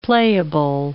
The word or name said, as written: playable